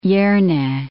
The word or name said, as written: Jerne